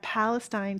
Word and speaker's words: Palestine: Koresh around 25 followers set up camp at Palestine, Texas, 90 miles, or for my Canadian followers, 140 kilometers. – Oh, You actually pronounced Palestine correct. Oh. That was a really nice Texan pronunciation.